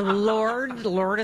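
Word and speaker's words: Lorde: So, Lord is how you say it? – Lord.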